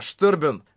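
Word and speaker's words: Sterben: Когда турнир закончился, я вышел, и Асуна рассказала мне, что это имя, Штербен, которым пользовался Дезган, немецкое слово, и именно так его надо произносить.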